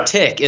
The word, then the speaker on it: Arctic Circle: Okay. Well, I call, yeah, Artic, right? Artic. – Artic. – Yeah. Artic is how it's said. However, it is Arctic Circle.